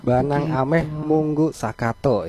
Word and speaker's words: Banang Ameh Munggu Sakato: Banang begitu. Ameh Munggu Sakato ya. Begitu ya, benar ya untuk pengucapannya ya.